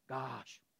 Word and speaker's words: Gaash: How would you pronounce that? G-A-A-S-H. Gaash, right? Gaash. You got to hold out the A.